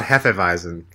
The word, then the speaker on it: Hefeweizen: What about Hefeweizen? – Oh, careful. Careful with that one. – Yeah. – It's Hef, not Hefe. Shout out to bartender Chuck the bartender.